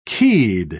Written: keyed

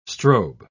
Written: strobe

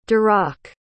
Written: darach

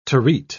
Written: terete

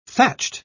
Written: thatched

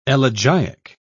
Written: elegiac

